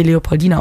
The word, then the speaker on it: Leopoldina: in Brasilien wird sie Leopoldina ausgesprochen, als ihre Schutzherrin ernannt haben, aufgrund der Beziehung zu der Lehrerin.